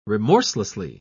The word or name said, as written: remorselessly